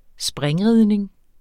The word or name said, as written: springridning